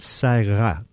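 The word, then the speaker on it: Ça Ira: Yeah, the opera is called Saira. – It's spelled C-A-I-R-A. – Saira.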